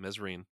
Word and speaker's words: Mesrine: But during this period of contact with Mesrine, which I just found out is how you say it, Mesrine.